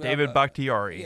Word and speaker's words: Bakhtiari: David Bakhtiari.